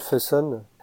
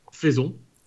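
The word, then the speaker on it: Faison: Pour moi, je le voyais écrit, je l'ai jamais prononcé, donc c'est la première fois que je le prononce Tu dis comment toi Faisons.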